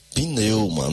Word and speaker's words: pneuma: No grego, a língua original do Novo Testamento, meu caro irmão, meu caro ouvinte, a palavra espírito é pneuma. Pneuma. É daí que vem a palavra pneu. Ela é usada 370 vezes no Novo Testamento. Viu? Pneuma. Anote aí. Pneuma. P mudo. Pneuma.